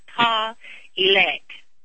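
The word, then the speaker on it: Pawelec: It's P as in Paul, A-W-E as in Edward, L-E-C, P-A-W-E-L-E-C. It's like Paul elect.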